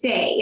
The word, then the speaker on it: Saie: I think it's pronounced Say. It's SAIE.